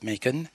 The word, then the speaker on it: Macon: C'est Macon, mais c'est en Géorgie, hein, donc ça se prononce Macon.